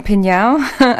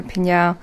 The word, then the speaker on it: PNAU: They're called Pinyao, another band that if you see it spelt, you can't say it. – P-N-A-U. – Yeah, in capitals. And if you want to remember how to say it, just think of meow, or you can just go Pinyao.